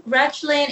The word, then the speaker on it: Rachlin: Rachlin. In Hebrew, it's Rachlin, but it's hard to pronounce in English.